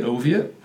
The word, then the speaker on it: Oviatt: They always called me Soviet Oviatt. And, giggled and poked fun at it. Nowadays, it's something that I use so that people can pronounce my name correctly, because it is a unique name that some people struggle with.